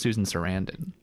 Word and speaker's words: Susan Sarandon: I've always heard it's Susan Sarandon. I think that's probably is the correct pronunciation.